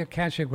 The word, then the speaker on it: Akashic: Is it Akashic Records, or how do you say that? – Yeah, the Akashic Records, I think it's on the causal plane where all of the records of our past life are stored, kind of thing.